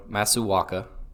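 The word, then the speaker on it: Masuaku: You could have Cresswell or Masuaka. I think I'm pronouncing that correctly. – Sounds good to my ears.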